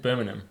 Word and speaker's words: Birmingham: Birmingham. – Am I doing it right? – That was good. That was Birmingham? Good, I think. – Birmingham.